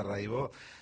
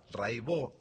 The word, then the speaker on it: Raibaut: Buenísimo, Cata Raibó. Se escribe Raibaut, métanse, búsquenla, Rai con Y, Baut con B larga. Catalina Raibaut, Raibó se dice.